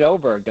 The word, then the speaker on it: Åberg: Yeah, this young kid, Oberg. – Ludwig Åberg, right? Isn't that how you say his name? – I think they pronounce it Åberg, don't they? – Åberg, that's right.